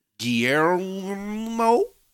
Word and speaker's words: Guillermo: Guillermo? – Yeah. – Why can I not pronounce his name? – I don't know. – Guillermo. – Guillermo. – Guillermo. – Guillermo. – You gotta roll.